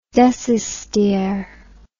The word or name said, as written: decistere